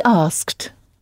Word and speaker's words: asked: He asked. – He asked, oui, qui s'écrit donc « a-s-k-e-d », mais qui se prononce comme une seule syllabe, avec le « ed » final prononcé « t ».